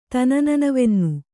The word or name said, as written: ತನನನವೆನ್ನು